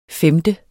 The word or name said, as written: femte